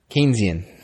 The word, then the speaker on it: Keynesian: Keynesian. K. – Sounds K-E-Y. Delicious.